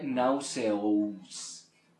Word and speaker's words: nauseous: ¿Cómo se pronuncia? Porque tiene una A-U. Esa es tu tarea. Se escribe nauseous. – Nauseous. – Good. Ese N. Ese sonido, no. Es una au, nauseous.